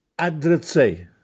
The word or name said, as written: Addrezzè